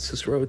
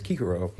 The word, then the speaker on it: Cicero: and that's spelled V-I-R-G-O, V-I-R-G-I-N-I-S. So it's just, it looks like virgin, meaning girl. Or the name Kikero. It's actually in the classical pronunciation. It's not Cicero, it's Kikero. It would be Kikeronis. It's a hard C, meaning Cicero or Kikero, the great Roman orator.